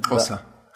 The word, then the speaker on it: Xhosa: Xhosa. – Xhosa.